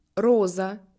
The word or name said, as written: rose